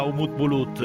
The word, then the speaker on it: Umut Bulut: Cioè, in realtà per me era Umut Bulut, perché io non sono un estremista delle pronunce, però questa fra i telecronisti ci sono varie scuole di pensiero,